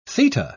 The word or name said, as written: theta